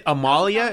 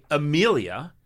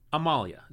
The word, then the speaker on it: Amalia: Hey, is it Amalia? Is that how I say your name? – It is perfect. Yeah. – Okay. Sometimes people say Amelia, but you say Amalia. Is that correct? – Yes.